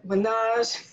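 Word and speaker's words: Ménage: How do you pronounce it? Because I don't want to say it wrong. – No, you're going to say it. Menage. – Menage.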